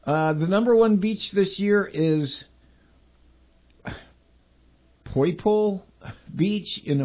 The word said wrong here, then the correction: Poipu: Okay, pronounce this one, Shannon. P-O-I-P-U. Poipo? Poipu? – Poipu. – Poipu. Poipu Beach in Hawaii.